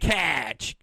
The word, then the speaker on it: Cache: So went to Cache, Oklahoma. – Spell that for me. – Cache. C-A-C-H-E. – I would have spelled it C-A-S-H.